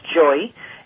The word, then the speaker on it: Joie: She said the puppy's name is Joy, J-O-I-E, which is pronounced Joy, and from a numerology point of view, he's a communicator.